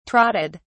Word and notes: trotted: British English

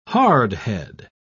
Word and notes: hardhead: British English

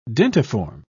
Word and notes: dentiform: British English

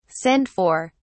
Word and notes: send for: British English

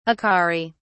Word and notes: acari: British English

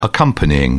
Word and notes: accompanying: British English

